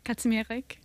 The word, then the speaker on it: Kazimierek: What is the proper pronunciation of it? – Kazimierek. – Oh, Lord. I did. And is that Polish? – Yes.